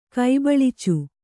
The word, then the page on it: ಕೈಬಳಿಚು: Kannada